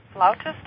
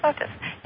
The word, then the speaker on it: flautist: You're also, I believe, a professional flautist. Am I saying that right? Right. – Yes. Yeah. Well, flautist, flute. – Yeah, flautist. – Either one's correct. – I like that language, flautist.